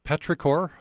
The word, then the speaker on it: petrichor: By the way, in this survey, you had 20 questions, you had your own, and you get in your favorite word, petrichor. Am I saying that correctly? – Yeah, I think it's such a beautiful word, the smell of rain before it falls.